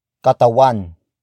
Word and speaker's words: Katawan: The next word is katawan. Katawan. Katawan. Katawan is spelled as K-A-T-A-W-A-N.